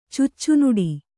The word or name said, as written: ಚುಚ್ಚುನುಡಿ